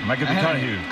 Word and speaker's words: McConaughey: Hay que ir corrigiendo la pronunciación. McConaughey.